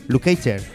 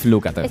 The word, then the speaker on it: Lukather: Dice: se pronuncia Lucater. – Lucater. – Eso no. – Bien, bien. – A ver, ¿di pronuncia Lucater? – Ajá. – Lucater. – Es Steve Lukather. – Steve Lukather. – No, Steve Lukather. O sea, quien diga lo contrario es mentira. Steve Lukather.